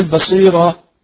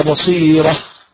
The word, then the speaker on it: بصيرة: طيب يا إسماعيل ما شاء الله عليك يا إسماعيل، خد بالك خد بالك في كلمة بصيرة، لا تقل بصيرة راء وكأن بعد الراء حرف الألف، لكن هي بصيرة، تمام؟ ولو ألقى معاذيرة، إذا لابد أن تنطق الهاء، التاء المربوطة عندما نقف عليها، تمام؟ نقف بالهاء الساكنة، يعني مثلا تقرأ فتقول بل الإنسان على نفسه بصيرة، ولو ألقى معاذيرة، تمام يا إسماعيل؟